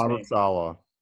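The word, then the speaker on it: Saleh: How do you pronounce his last name? – Robert Sala. – It's Robert Sala.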